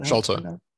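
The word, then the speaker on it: Sharlto: And everything that you've done, I mean, you brought him to us essentially, to the world, but like, I'm going to pronounce his name incorrectly, but Shartlow, is that Shalto? – See, thank you.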